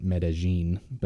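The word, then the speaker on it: Medellín: She pronounces it a little different than I would have pictured, but I don't know, I'll trust her. But she says it Medellin, but it's M-E-D-E-L-L accented I-N, so I would have thought it would have been like Medellin, but I'll trust her on that.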